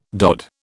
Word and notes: DOD: British English

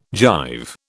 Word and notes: jive: British English

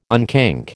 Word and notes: unkink: American English

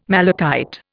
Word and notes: malachite: Indian

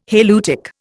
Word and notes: halieutic: Indian

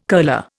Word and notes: curler: Indian

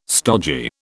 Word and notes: stogy: British English

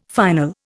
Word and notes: final: Indian

